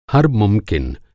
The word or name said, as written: मुमकिन